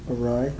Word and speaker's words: awry: How do you pronounce that? – Awry. – Awry.